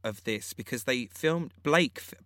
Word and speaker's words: Black: You don't pronounce it Blake. – No, not Blake as in Blake. Like, not Blake, it's Black.